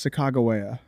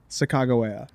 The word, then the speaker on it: Sacagawea: It's Sacagawea. – Is it really? – Apparently, like. – What are you talking about? – Apparently, that's like the real pronunciation. Sacagawea.